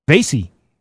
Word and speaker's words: Vasey: Vasey. There we go. I just beat you to it. Vasey. I should have realized that. The name Casey with a V, idiot.